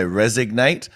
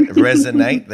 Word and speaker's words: resonate: I had a listener, Chelsea, just so you know, pick me up on the fact that I used to say resignate, and they took the time to DM me and let me know that I was saying it wrong. So that person who's still listening, I said it right, resonate.